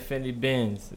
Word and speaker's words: Finny Benz: Yeah, shout out that nigga Finny Benz. Make sure you pronounce that right. That's Benz like you driving.